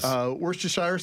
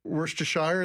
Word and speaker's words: Worcestershire: Worcestershire sauce? – Yes. – Have you been able to pronounce that? Worcestershire is the way my mom used to say it. – I say Worcestershire.